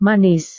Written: manis

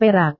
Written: perak

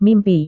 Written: mimpi

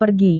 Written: pergi

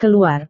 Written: keluar

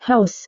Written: haus

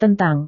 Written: tentang

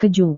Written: keju

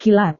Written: kilat

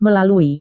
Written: melalui

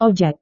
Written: ojek

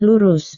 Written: lurus

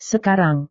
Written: sekarang

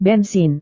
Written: bensin

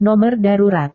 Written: nomor darurat

